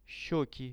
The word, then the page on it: sheki: Russian